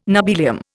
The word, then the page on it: nobelium: Indian